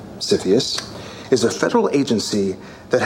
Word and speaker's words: CFIUS: So there's this body in the government called the Committee on Foreign Investment in the United States, which is abbreviated and pronounced CFIUS. – CFIUS.